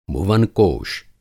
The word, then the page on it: भुवनकोश: Hindi